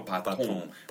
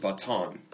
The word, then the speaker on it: baton: 그러면은 이것도 영어에서 들어온 것 같은데 불어식 발음으로 하네요. 바통. – 바통. – 우리는 미국 사람들은 바톤. – 바톤. – 네, 그 이응 소리 아니지 그냥 엔 소리.